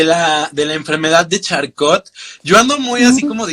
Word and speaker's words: Charcot: Charcot, dilo como hablando en español, Charcot.